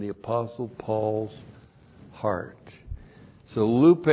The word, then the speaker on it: lupe: So I have great, point three, lupe. L-U-P long E. Lupe.